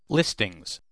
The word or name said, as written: listings